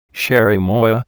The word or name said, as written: cherimoya